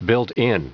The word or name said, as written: built-in